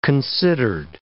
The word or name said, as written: considered